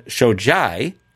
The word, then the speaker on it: Shojai: So it's Amy with a Y, and it's spelled Shojai, S-H-O-J-A-I.